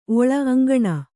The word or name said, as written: ಓಳಿ